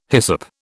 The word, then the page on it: hyssop: British English